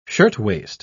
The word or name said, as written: shirtwaist